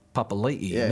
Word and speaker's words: Papali'i: Yet, I swear it was only just last year we went from Papali to Papali'i. – Yeah. – And I think to myself, look, it's your name. We should pronounce it right.